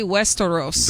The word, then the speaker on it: Westeros: That would be Westeros.